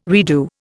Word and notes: redo: Indian

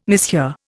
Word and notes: mishear: Indian

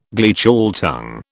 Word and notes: Gleichschaltung: British English

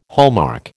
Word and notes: hallmark: American English

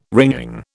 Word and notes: wringing: British English

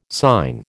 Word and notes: syne: American English

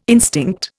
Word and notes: instinct: Indian

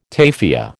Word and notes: tafia: American English

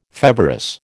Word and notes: feverous: American English

